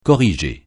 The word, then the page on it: corriger: francais